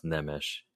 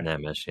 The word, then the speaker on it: Nemes: My last name actually is pronounced Nemes. – Nemes, okay. – Nemes, yeah.